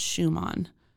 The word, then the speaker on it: Šuman: But and I actually said Suman earlier. I think it's pronounced Schumann. – Really? – Because it has that weird little S, that accent thing over the S.